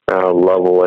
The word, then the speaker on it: Louisville: Going to be at Archery Trade Association in Louisville, Kentucky. Lovell, I think, is how they say it, but it's Louisville. And we got some products that'll be hitting the shelves sooner or later.